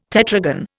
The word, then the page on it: tetragon: Indian